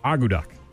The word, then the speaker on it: akutaq: And it's spelled A-K-U-T-A-Q. Agu duck.